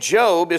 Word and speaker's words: Job: Now, number six, the name Job is pronounced with a long O sound.